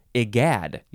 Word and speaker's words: egad: Now, I would want to say egad, I guess, but it says it's an I sound at the beginning. I, yeah, egad. You have to emphasize the second syllable. Egad. E-G-A-D.